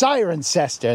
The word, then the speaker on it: Cirencester: And you'd go, no, you idiot. It's pronounced Cirencester.